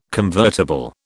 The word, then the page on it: convertible: British English